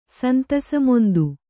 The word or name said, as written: ಸಂತಸಮೊಂದು